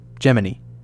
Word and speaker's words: Gemini: If you're balking at my pronunciation, you're in my shoes. If you saw the film First Man, which came out last year, they said it this way throughout the movie, and Brendan said it the same. The Gemini space program is pronounced like that, Gemini. Why? The answer is simply because. Most of the astronauts and administrators called it Gemini, so we will too.